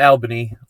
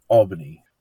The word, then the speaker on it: Albany: And I said it wrong, the first time I said Albany, right? – Yep, not Albany, Albany.